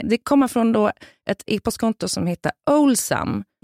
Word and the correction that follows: Owlsam: Kanske Owl. – Owlsam, ja. – Som är uggla. – Jag visste ju att det var uggla, jag uttalade det fel.